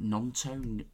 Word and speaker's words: Gnonto: Is it Gnonto? Is a silent G, I think.